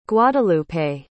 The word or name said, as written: guadelupe